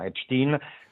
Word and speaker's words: Epstein: o Epstein, pronunciamolo all'americana, Epstein, per traffico di minorenni, ragazze e ragazze, insomma dei minorenni.